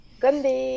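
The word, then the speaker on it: Gombe (건배): Et voilà, et donc qui euh, fait le podcast, et je te laisse prononcer parce que j'ai pas envie de le refaire. Gombe.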